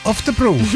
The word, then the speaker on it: Oftebro: Oh, oh yeah, the guy, I can't pronounce his name. Nils Ulla Oftenberg. – And the winner was Nils Ulla Oftebro.